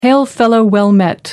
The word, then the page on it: hail-fellow-well-met: American English